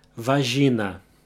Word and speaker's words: vagina: The only thing that changes is the pronunciation of the middle syllable. Instead of jai, it's ji. Vagina.